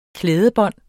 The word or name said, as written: klædebon